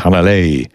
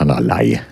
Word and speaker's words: Hanalei: Der Titel übrigens Hanalei, nicht Hanalei.